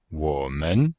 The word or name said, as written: wo3men5